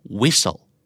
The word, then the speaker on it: whistle: whistle W H I S T L E whistle ไม่ออกเสียงตัวทีเล